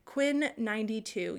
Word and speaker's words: Quinn XCII: Apparently it's pronounced Quinn 92.